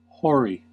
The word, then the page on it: hoary: American English